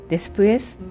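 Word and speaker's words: después: 主語の次に間接目的語を持ってきてそれで動詞という順番なんですが、ジョテエスクリボでジョは省くことができるのでテエスクリボで、後でという単語はデスプエスという単語があるんですね。でこのデスプエスっていうのは副詞なんですけれども DESPUES と書いて、 2 つ目の方の E の上にアクセントがつきます。デスプエスで、このデスプエスの位置なんですけれども、スペイン語の場合はね割と副詞の位置というのはそんなにきっちり決まっていなくて、前につけても後につけても別にいいんですね。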